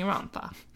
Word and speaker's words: Rhondda: That is spelled like Rhondda with an extra D, but apparently it's Rhondda.